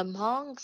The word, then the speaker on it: Hmong: So Hmong, it is spelled H-M-O-N-G. In the Hmong spelling, it's H-M-O-O-B, Hmong.